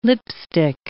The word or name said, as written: lipstick